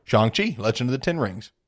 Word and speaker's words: Shang-Chi: On Shang-Chi. Correct pronunciation. I corrected myself.